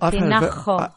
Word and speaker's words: Tinajo: But no, I'd call that Tinajo. You see. – It's a long A. – Yeah, there we go. Tinajo.